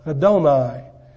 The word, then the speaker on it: Adonai: Adonai, A-D-O-N-A-I. It's pronounced almost like E-D-O-N-A-I.